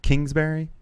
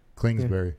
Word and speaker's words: Kingsbury: Kingsbury? Like a king with a crown? – Yeah. You call Cliff it Clings. – Clingsbury.